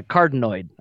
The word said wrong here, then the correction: cardioid: I believe. – There you go. – Super cardinoid, yeah. – Carteroided? It's a carterized? – Super cardioid.